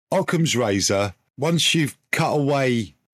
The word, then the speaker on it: Occam: It's Occard's razor, isn't it? – Occam's razor. – Occam, not Occard's.